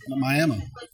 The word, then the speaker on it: Miami: Yeah, I think in Miami was, I think the way to pronounce it was Miami.